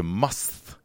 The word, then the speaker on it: musth: bull elephants go into must. Must. – What? How do you spell that? – M-U-S-T-H. You can also say must. If you must, you can pronounce it must.